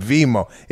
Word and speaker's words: Venmo: That's why Lou and I were dying with Venmo.